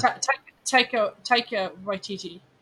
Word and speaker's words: Taika Waititi: It's Taiki Waititi. – Yeah. – Taika Waititi.